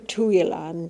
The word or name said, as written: Tooilan